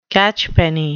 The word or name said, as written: catchpenny